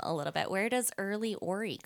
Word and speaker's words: RLYORY: So R-L-Y, and that's Early, and then I am Ophelia Rose Youngberg, so that's O-R-Y, so it's Ori. So Early Ori. – So it's your initials, but it's spelled R-L-Y-O-R-Y.